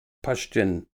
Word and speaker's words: Pashtun: The Pashtun, Pashtun with the U pronounced as in up, are the white people, or Euro-Americans, as described in Western academia's racialized narrative.